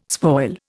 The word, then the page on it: spoil: Indian